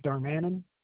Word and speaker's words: Darmanin: Joseph Darmanin. – How do you spell that last name? – D-A-R-M-A-N-I-N.